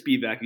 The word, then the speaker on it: Spevack: So my last name is Spevack. You said it right. So I know, like, my basic training, like my boot camp drill sergeant instructors, he fucked it up. He was like Spevack, and everyone's corrected him.